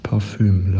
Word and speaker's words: Parfüm: Im Französischen wird das Wort in der Tat Parfum ausgesprochen. Im Deutschen ist meines Wissens nach sowohl die Rechtschreibung mit U als auch die Variation mit Ü gängig. Das lässt natürlich darauf schließen, dass im Deutschen die korrekte Aussprache in der Tat Parfüm lautet.